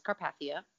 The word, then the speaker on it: Carpathia: So 8 hours later, they're rescued by the RMS Carpathia. – Mm-hmm. – Am I pronouncing that correctly? – Yeah.